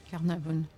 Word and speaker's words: Carnarvon: y compris celui de Lord Carnarvon. Je le dis bien, oui. – Carnarvon. – Ouais.